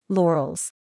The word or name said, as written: Laurales